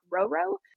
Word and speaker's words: Rou Rou: Oftentimes, I'll have people think that my name is just Rou and not Rou Rou, thinking that it's a nickname of sorts.